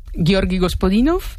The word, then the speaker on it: Georgi Gospodinov: Ayúdanos a pronunciar los autores. – A ver, Georgi Gospodinov, sí. Zdravka Eftimova y Vera Mutavcheva.